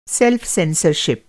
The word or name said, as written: सेल्फ़-सेंसरशिप